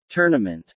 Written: tournament

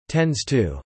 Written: tends to

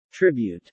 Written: tribute

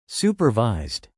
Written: supervised

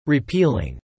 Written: repealing

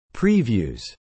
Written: previews

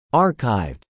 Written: archived

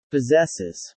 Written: possesses

